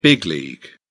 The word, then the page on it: big league: British English